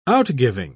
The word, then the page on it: outgiving: British English